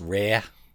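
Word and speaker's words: RAR: Yeah, it's R-A-R Brewing. So it's capital R, capital A, capital R. It stands for something. – It's not pronounced rare? – No, it's not. It's not Boston.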